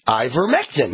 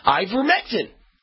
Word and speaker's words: ivermectin: Let me just make sure I'm pronouncing it right. Here we go. Okay, so it is ivermectin. Yeah, ivermectin. And it's on the approved list by the World Health Organization.